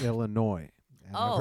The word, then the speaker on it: Illinois: Illinois, the S is silent.